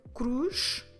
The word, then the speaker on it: cruz: C, C-R-O-C-O-D-I-L-O. Crocodilo. Cruz. A cross. Como se escreve cruz? C-R-U-Z. Cruz. Okay, so every time you have a C-R, C-R, you need to also have a hard Q.